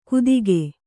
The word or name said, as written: ಗುದಿಕೆ